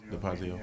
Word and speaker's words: DeFazio: DeFazio. – DeFazio.